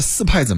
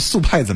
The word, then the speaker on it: 速派: 这个戚坤说了，苏柯达的四派怎么样呢？斯柯达的速派怎么样呢？我怎么念瓢嘴了？